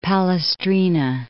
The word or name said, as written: Palestrina